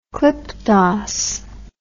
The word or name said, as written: klipdas